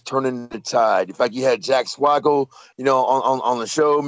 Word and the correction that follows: Swagger: No, you didn't. You said Jack Swaggle. – Swagger? – There you go. – Swagger!